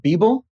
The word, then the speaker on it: Beoble: You're the CEO of, it's pronounced Beeple? – Yes, it's the upside down of people. – Ah, it's the upside down of people. So it's spelled B-E-O-B-L-E.